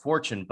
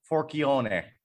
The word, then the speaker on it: Forchione: So they changed the pronunciation of Forchione, but the Italian way to say it is Forchione.